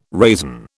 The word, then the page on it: raisin: British English